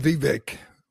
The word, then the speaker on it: Vivek: I said Vivek rhymes with cake.